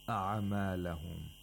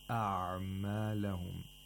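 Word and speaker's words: أعمالهم: We have to pronounce it without قَلْقَلَة. We're not supposed to say أَعْمَالَهُمْ. That's incorrect. Or if we say أَعْمَالَهُمْ is also incorrect. That would be with اختلاص, with too much اختلاص. And that's also incorrect. It's أَعْمَالَهُمْ. أَعْمَالَهُمْ. You pronounce the عين completely and then you pronounce the mean.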